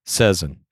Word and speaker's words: Seson: The correct pronunciation of S-E-S-O-N, Sezen.